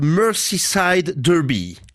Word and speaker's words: Merseyside Derby: Alors commençons par The Merseyside Derby. Je le dis bien. – Pas trop mal.